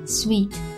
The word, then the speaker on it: suite: 比如说单人间 single room，双人大床间我们刚才说到的 double room，双人双床房 twin room，家庭房 family room，还有一种我们出差的时候会经常用到的套房套间 suite。这个词需要特别注意一下，它和西装 suit 这个词长得非常像，只多了一个 e，但是发音很不一样，发错的同学可能还不少。它和甜甜的糖果是一模一样的发音。